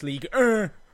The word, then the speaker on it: Ligue 1: Ligue 1. Mm-hmm. My French pronunciation is horrible. – It's Ligue 1. – Ligue 1.